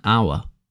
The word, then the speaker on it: hour: Funnily enough, this hour actually starts with a H. How many hours? Although because the H is silent and the letter that comes after it is a vowel we use the A-N article instead of A.